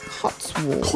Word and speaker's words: Cotswolds: C-O-T-S-W-O-L-D-S. – Cotswolds.